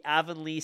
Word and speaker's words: Avonlea: The Avonlea Sask product.